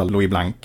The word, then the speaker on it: Blanc: Och så finns det en som stavade, som man tänker någon som skulle heta Louis Blanc, som också är socialist i Frankrike den tiden, men jag tror att han uttalar man utan K.